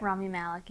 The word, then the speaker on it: Rami Malek: Rami Malek, yeah.